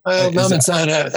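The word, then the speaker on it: Lamin Sanneh: I said Laman Sane. How do you say it? – Laman Sane. – Sane? – Yeah.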